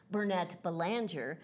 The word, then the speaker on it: Burnett-Belanger: Well, my last name in the Americas, in the United States, is Burnett Belanger.